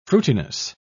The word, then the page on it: fruitiness: British English